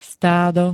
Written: stádo

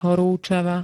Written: horúčava

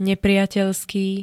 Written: nepriateľský

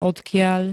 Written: odkiaľ

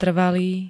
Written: trvalý

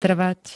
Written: trvať

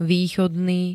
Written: východný

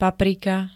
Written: paprika

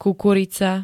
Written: kukurica

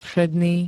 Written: všedný

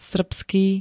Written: srbský

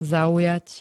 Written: zaujať

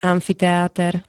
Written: AMFITEÁTER

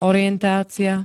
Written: orientácia

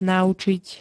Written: naučiť

